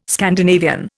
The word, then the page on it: Scandinavian: Indian